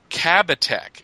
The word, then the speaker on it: Kabatek: Oh, sorry, I didn't pronounce that at all. I don't know why I said Katabek. Kabatek, excuse me, and he was one of the people that brought DC to Germany.